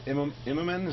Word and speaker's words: Immonen: Hey, Brian, I wanted to ask you about Ultimate Spider-Man with Stuart Immonen.